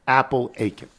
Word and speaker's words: Apalachin: So in reference to the mob meeting in the town of Apalachin, it's commonly pronounced like Apalachin. Apalachin.